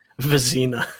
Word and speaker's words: Vezina: I'm not sure about Vezina or Vezina or however you want to say it. I always say it wrong. – Vezina.